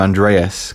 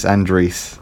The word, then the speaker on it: Andreas: Or, well, I will say another meme name in the community, because everyone calls him Andreas, because it's spelt irrefutably spelt Andreas. – Can I hazard a guess? – Phonetically spelt Andreas. Yeah, go on, guess how it's supposed to be pronounced. – Andries. – Yes, it's Andries.